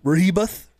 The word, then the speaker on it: Rehoboth: That cannot be how you say Rehoboth. – It's spelled Rehoboth. Rehoboth. – Yeah. – Rehoboth? – Let's see if they have the pronunciation on the Wikipedia page. The E is upside down. – Ray? – H-O-H- B upside down E T H. – Oh, okay. Rehoboth.